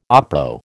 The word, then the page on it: oppo: American English